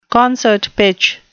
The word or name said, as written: concert pitch